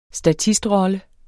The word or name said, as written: statistrolle